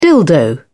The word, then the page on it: dildo: British English